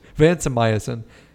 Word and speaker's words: vancomycin: Vancomycin. Yeah. Sure. – We need some of our pharmacy friends from back in college. Vancomycin.